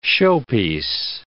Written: showpiece

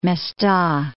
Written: Mesta

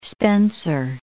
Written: Spencer Gulf

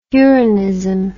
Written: uranism

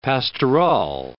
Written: pastorale

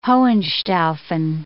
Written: Hohenstaufen

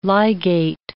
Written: ligate

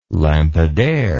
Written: lampadaire

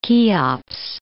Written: Cheops

